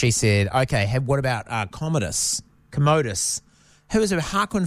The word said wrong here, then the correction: Joaquin: Phoenix played. Joaquin. Sorry, I say that wrong every time.